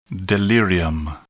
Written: delirium

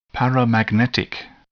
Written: paramagnetic

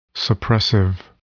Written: suppressive

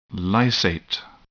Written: lysate

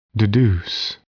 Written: deduce

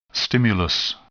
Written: stimulus

